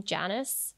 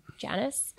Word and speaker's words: Janus: Actually, I think you pronounce his name Janus. – So How do you spell it? – J A N U S. – Okay. – Janice.